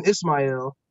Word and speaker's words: Ismail: And he took that and said, instead of the Hebrew pronunciation, Ishmael, he took the Arabic pronunciation, Ismail.